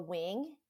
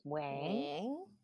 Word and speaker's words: Nguyen: I'm Sheila Nguyen, or Wing, if you want to say it proper, Vietnamese. – How do you say it? – Yes, that's how you say it. – Wing. – Yeah, like a bird's wing.